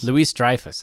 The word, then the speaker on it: Louis-Dreyfus: I mean, I guess we're full spoilers now for this movie, obviously, but, like, Julia Louis-Dreyfus, right? That's her name? was in this movie. Just she was in it. – Julia Louis-Dreyfus. – Louis-Dreyfus. That's how you say it.